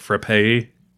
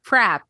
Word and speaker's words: frappe: We've talked at the distillery about how as the winter approaches, we should set up a blender so we can make like frappe drinks. – Mm-hmm. Crab. – Did you say crab? – Frap. – Oh, frap. No, whatever. – That's how it's pronounced in Massachusetts.